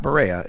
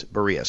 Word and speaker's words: Berea: I said it wrong a few times, and people let me know. Not Berea, it's Berea. So it's in Berea, and it's a nice training complex.